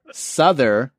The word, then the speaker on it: Sotheby's: He says it's pronounced Souther, like mother bees.